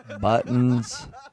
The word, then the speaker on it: Buttons: Buttons. You know, like the thing on your shirt? – Buttons.